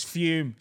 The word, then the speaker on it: Fum: It's Fume, spelled F-U-M, but pronounced like it's got an E on it.